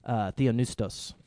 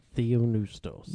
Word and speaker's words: theopneustos: Theopneustos. – Theopneustos. – Yeah. – That P there is silent. – Yeah, it is. Well, technically it's not silent, but we're not going to get into the Greek.